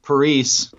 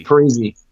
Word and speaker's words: Parise: Based on our interview yesterday with Zach Parise, I'm still not saying that right, am I? – Parise.